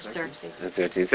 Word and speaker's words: Xerxes: With an X-E-R, Xerxes or something? – I think it's pronounced with a Z. – Yeah. – Who was it? Xerxes.